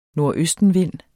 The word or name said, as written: nordøstenvind